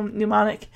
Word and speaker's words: mnemonic: And of course, Davy Chu spells it correctly, and he puts so much emphasis on the fact that there's a silent M. He's like, M N E. How however you spell mnemonic.